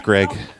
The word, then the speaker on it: Gregg: S-A-N-T-A. – No, like your actual name. – Okay, it's Greg. – How do you spell your name? – G-R-E-G-G.